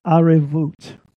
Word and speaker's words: arevut: Number four is the word arevut. A-R-E-V-U-T. A-R-E-V-U-T. Arevut. And when you see this U-T, that's going to make it plural too. It's the same spelling as O-T, just pronounced differently.